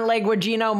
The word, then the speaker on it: Leguizamo: Does it star John Leguizamo Mamas? – That is the correct pronunciation of his name.